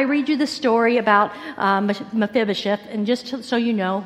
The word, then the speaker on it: Mephibosheth: You pronounce it Mephibosheth. Okay? Say it with me. Mephibosheth.